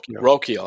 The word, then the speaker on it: Rocchio: Rocaccio. I always get this name wrong. – Rocchio.